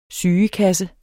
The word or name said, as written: sygekasse